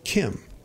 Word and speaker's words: Chim: Now, Kim, when you see it in the lore, is spelt C-H-I-M, but it's actually pronounced Kim.